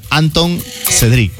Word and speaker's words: Antoine Cedric: Alejandra Delgado y Anton Cedric. Así se llama, ¿no? Se escribe Antoine Cedric. Bueno, o se pronuncia tal cual.